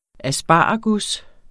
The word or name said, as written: asparagus